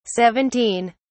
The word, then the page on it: 17: British English